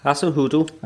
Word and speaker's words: Hasenhüttl: Hasenhüttl.